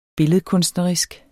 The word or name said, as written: billedkunstnerisk